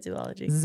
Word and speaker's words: zoology: Everybody said zoology. – Supposed to rhyme with zoology. Eulogy.